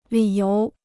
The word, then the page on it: 理由: lǐ yóu